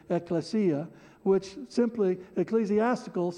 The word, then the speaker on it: ekklesia: And the best place to start is the very definition of the word church, which is the Greek word ekklesia.